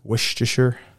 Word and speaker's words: Worcestershire: How did you pronounce that? – Worcestershire. – Is that correct? – He said it correct, yeah.